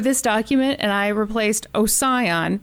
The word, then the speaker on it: Ossian: So I went on the YouTubes and there were like four different pronunciations that I heard, but most of them were basically ocean.